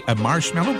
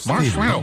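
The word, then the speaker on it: Marshmello: La preuve que vous êtes au meilleur de la musique, c'est Marshmallow, comme ça qu'on le prononce. – Ouais, tu l'as bien. – Marshmallow.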